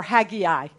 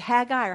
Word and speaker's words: Haggai: Or Haggai, so some of you are going to say, is it Haggai or Haggai? It is pronounced both ways, depending on where you're from. So I may do it both ways as well.